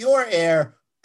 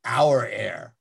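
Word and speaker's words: Aur Air: Well, on the topic of home health, a similar topic, I guess, there was a product from Our Air, I believe, A-U-R Air. – Yeah, and they put that in the release. It's pronounced Our, not Your Air, Our Air.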